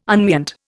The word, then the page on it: unmeant: Indian